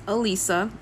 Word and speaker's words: Elisa: Um my name is Elisa, so don't emphasize so hard on the Z.